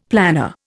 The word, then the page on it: planner: Indian